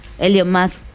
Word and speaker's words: Elon Musk: Elon Musk.